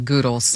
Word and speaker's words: Goodles: Goo as in goo? G-O-O? – Yeah. Oh, it is called Goodles. – Yeah. Yeah. Goo. Like rhymes with spoo.